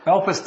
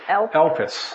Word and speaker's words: elpis: How to say it again? – Elpis. – Elpis.